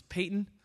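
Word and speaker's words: Paton: I think that's why we get Patton, because he pronounced it Patton, but anyone from Scotland will vigorously tell you it's Peyton.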